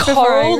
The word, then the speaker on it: Korrel: It's Korrel, like in the ocean, but I don't mind if you say Korrel because then people know how to spell it.